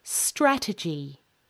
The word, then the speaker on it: strategy: The stress in this word is on the middle syllable. Strategic. La la la. Strategic. This is different to the word strategy, which has the stress on the first syllable.